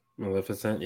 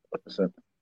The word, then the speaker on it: Maleficent: And then let's go with Maleficent. Hopefully I said it right. – Maleficent, yeah. – Yeah, Maleficent.